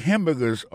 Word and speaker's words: hamburger: N'a pas remarqué que dans le mot hamburger, ça a comme tu as dit, il y a trois syllabes là-dedans, quand tu appuies et puis plus ça, c'est sous premier syllabe là.